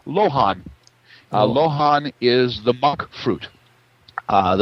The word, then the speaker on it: luohan: One of the ways it's spelled is L U O H A N, but pronounced Lohan, it also has a soluble fiber inside the fruit, which isn't sugar.